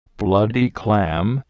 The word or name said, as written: bloody clam